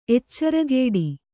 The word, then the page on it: ಎಚ್ಚರಗೇಡಿ: eccaragēḍi